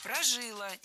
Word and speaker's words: прожила: Вот это, понимаете, она прожила все необходимое, всю подноготную, всю обыденную жизнь до 16 лет. – Прожила, неверно, правильный вариант ударения – прожила. Ударяем последний слог, окончание.